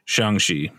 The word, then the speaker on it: Shanxi: I think in Mandarin, how do you pronounce the X? Is that a Qi? Or is it a? – It depends. Like this is pronounced Shanxi.